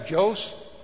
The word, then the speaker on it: Jos: but there's a capital in Nigeria called Jos, J-O-S, or Jos. How do you say the word? – Jos. – Okay, Jos, Nigeria.